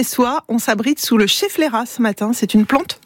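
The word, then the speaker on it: Schefflera: Bon, au moins s'il pleut, on est à l'abri avec le chef Lera. – Vous l'avez bien prononcé, le chef Lera qui est une plante d'intérieur et on l'appelle la plante parapluie.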